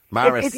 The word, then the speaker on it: Maris: Yeah, now they're giving out to me, but somebody's in my ear here giving out to me about Mars Pipers. It's Mars Pipers. Maris.